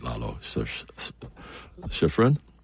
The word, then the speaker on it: Lalo Schifrin: Lalo, I'll say it right, Lalo Schifrin.